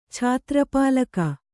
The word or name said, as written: ಛಾತ್ರಪಾಲಕ